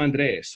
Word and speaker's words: Andrés: But others might say, no, I am Pedro, or I'm Andres, or for me, it's very important to put the accent on the E of my name,